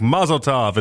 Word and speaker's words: Mazel Tov: So it's supposed to be like a rhymes with love at the back end of it, like a tov type thing, like a mazel tov.